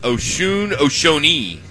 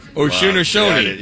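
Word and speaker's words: Osunniyi: This guy from St. Bonaventure is pronounced Oshonee. – Good job. Oshonee.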